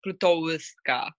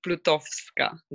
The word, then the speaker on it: Plutowska: You didn't say like Plutowska. – So in Polish, do they pronounce the W's kind of like the Germans do as a V? – Yeah, it's like V, exactly. So it's like Plutowska.